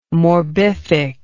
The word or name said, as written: morbific